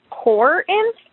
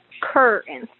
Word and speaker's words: Corinth: Currently, it says it's Corinth, and that's not correct. It is not Corinth. It would be Corinth, like a C-U-R, Corinth. Corinth.